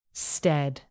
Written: stead

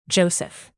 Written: joseph

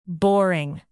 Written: boring

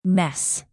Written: mess

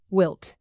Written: wilt

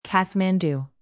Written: kathmandu

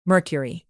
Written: mercury

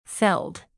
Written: felled

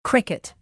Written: cricket

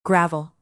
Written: gravel

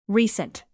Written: recent

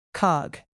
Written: cog

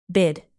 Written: bid

